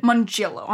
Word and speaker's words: Mongillo: What's the emphasis on? – Mongillo, right? – Mongillo, on the G.